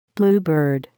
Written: bluebird

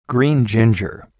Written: green ginger

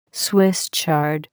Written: Swiss chard

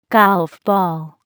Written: golf ball

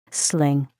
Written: sling

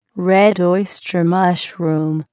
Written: red oyster mushroom

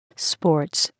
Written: sports

